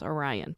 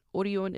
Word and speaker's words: Orion: Very similar to Lotte Entertainment and CJ Entertainment, Showbox comes from a food manufacturing company called Orion, or you might pronounce it as Orion, but Orion is how it's pronounced in Korean.